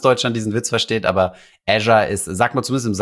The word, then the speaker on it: Azure: Azure ist es wirklich, schreibt man wirklich Azure.